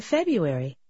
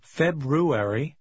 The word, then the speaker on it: February: In February. Airy. Airy. You. Bew. Buary. Feb. February. In. In February. – Также вы можете услышать, что иногда произносят February. – Обе формы правильные.